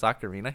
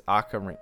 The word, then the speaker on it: ocarina: It's pronounced, I think it's ocarina. Ocarina, like A-H-K. – Yeah, it's ah. I always said O.